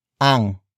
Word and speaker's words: ang: Word for word, it's ilan, ang, kamatis, sa, basket.